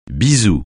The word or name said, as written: bisou